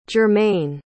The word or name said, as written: jermain